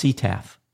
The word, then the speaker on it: CTAF: or CTAF, pronounced CTAF.